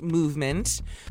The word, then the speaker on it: MVMT: My MVMT, it's pronounced movement.